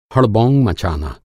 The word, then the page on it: हड़बोंग: Hindi